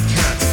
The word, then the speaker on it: Katz: By the way, my last name is spelled Katz, K-A-T-Z.